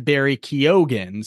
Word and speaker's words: Barry Keoghan: who told me that we were pronouncing Barry Keoghan's name wrong. It is, in fact, Barry Keoghan.